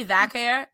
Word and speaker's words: Vaquer: Am I saying her last name right? Is it Vaquer or Vaquer? – Yeah, it's Vaquer is in Spanish. You said it right. And then in English, it's Vaquer. You got it in both languages.